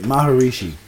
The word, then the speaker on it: Maharishi: Maharishi. That's how you say it. Maharishi.